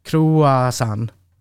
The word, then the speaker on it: croissant: Croissant. Croissant. Croissant. Ja, san. Det är bara ett n.